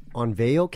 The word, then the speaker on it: Onavo: There is one called, it's hard to pronounce, but it's called OnVeo Count. And so it's spelled O-N-A-V-O.